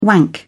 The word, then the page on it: wank: British English